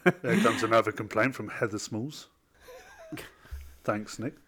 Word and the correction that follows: Small: It's Heather Small. – Yeah, Small, not Smalls.